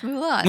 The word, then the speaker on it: Moulin: Is it Moulin? Am I saying it right? – Yeah, it's Moulin.